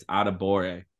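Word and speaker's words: Adebawore: It's Adebore. Bore. Just bore. The W is silent.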